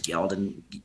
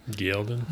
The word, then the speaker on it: Gealdan: I like this addition because we hear that he conquers Gildan. How do you guys pronounce it? – Gildan? – I'm in the Gildan camp. – Gealdan. Okay.